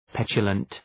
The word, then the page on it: petulant: ‘petʃələnt